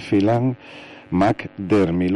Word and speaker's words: Phelan-McDermid: que es el síndrome de Phelan-McDermid.